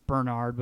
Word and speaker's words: Barnard: It's Bernard, but it's spelled Barnard.